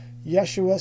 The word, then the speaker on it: Yeshua: And not only that, but there's not even a consensus regarding how this name was properly pronounced in Hebrew. Yeshua seems to be the most common uh uh, uh, uh, uh, pronunciation that we think of, but there was also a longer form, Yahashoah. Some people have uh, have claimed that it's pro- properly pronounced Yahshua, and there's even a, a, form that we know that we found in documents that's Hoshea, which is the the reference to the exact same name.